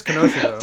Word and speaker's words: Kenosha: But, is it pronounced Kenosha? – This ain't the X-Men. – It is Kenosha. Though. – That's Kenosha. Okay. – Yeah. So the K is not silent. So it is Kenosha. – Okay. Kenosha.